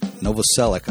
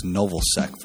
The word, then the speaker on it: Novoselic: But Novoselic, I always used to think it was Novosek for some godforsaken reason, and that was in my head when I was trying to pick his name out of the hat.